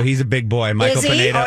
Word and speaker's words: Pineda: And I observed this guy named Pinata. I don't know if I'm saying his name right, the pitcher. – Pineda.